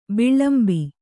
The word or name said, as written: ಬಿಲಿಂಬಿ